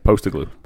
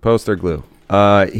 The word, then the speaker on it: Postecoglou: Postecoglou, yes. – Is how his name Poster is pronounced. – Glue? Yeah, Postecoglou. – Postecoglou.